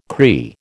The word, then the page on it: Cree: American English